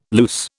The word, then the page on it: luce: British English